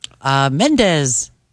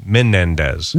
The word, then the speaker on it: Menendez: Mendez. – Close. You got to add another syllable in there. – I don't know. – Menendez. – Menendez. I thought it was Mendez.